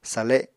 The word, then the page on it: sale: apatani